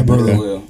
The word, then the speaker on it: Burlew: Is it Burlew? Is that how you say your last name? – Yeah, Burlew.